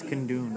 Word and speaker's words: Kundun: It's Kundun.